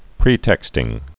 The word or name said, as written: pretexting